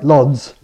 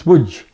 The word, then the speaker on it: Łódź: As you notice, I said my grandmother came from a place that looks on the map like Lodz, but it's Łódź, because the L's got a stroke through and the O's got an acute accent on it.